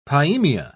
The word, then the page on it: pyemia: British English